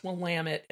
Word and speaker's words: Willamette: I think that's pronounced Willamette, if I'm not Willamette? Mistaken.